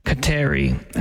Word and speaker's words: Kateri: The Mohawks pronounced Catherine as Kateri, hence the name Kateri Tekakwitha.